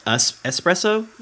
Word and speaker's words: espresso: So is espresso really supposed to be pronounced espresso? – Yes. – I didn't know that. – Oh, yeah. There's no X on it.